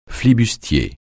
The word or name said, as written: flibustier